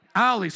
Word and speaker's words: Allie: After you've then puked, then I would ask you to watch the interview with Ali, how do you say her name? A-L-L-I-E. Ali, so I said it right, Ali. I was thinking like the road, like a street there,